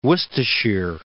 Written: Worcestershire

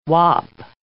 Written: wop

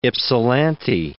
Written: Ypsilantis

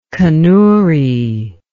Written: kanuri

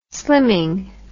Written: slimming

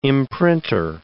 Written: imprinter

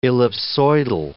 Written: ellipsoidal

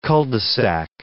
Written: cul-de-sac

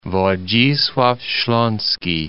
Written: Wodzislaw Slaski

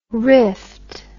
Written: riffed

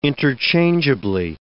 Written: interchangeably

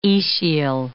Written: aecial

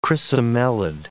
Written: chrysomelid

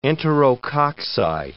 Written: enterococci

